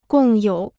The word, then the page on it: 共有: gòng yǒu